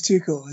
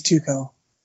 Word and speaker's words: Tuchel: The reason why it's Thomas Tuchel is because that's just how you pronounce the U in German, and CH is a K. So it is Tuchel.